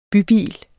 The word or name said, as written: bybil